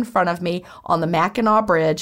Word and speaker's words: Mackinac: The Mackinac Bridge sounded like a hick from Georgia. But she told me to pronounce it Mackinac.